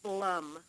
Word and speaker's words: Blum: It's Christine, C-H-R-I-S-T-I-N-E, middle initial E for Elizabeth, and last name Blum, B-L-U-M.